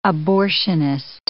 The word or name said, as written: abortionist